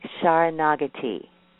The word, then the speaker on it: Saranagati: I say Saranagati.